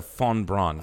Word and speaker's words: von Braun: It's Wernher von Braun. I know. The V is pronounced like an F. Like Volkswagen.